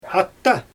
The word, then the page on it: ata: Xyzyl